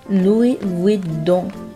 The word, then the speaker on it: Louis Vuitton: LV 大家都知道，在读的时候要注意 S 是不发音的，正确的读音呢应该是 Louis Vuitton。字母 T 要浊化成低音，最后的一个音 don，记住要通鼻音哦。